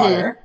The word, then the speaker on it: water: They were like, hey, here you don't pronounce T's. The T's are silenced right in, you know, when you're when you're speaking. – So something like water you mm-hmm. – water.